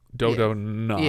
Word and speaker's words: Dodonna: Am I saying that right? Dodonna? D-O-D-O-N-N-A. Dodonna? That's got to be it. Dodonna. – Yeah, I would say Dodonna.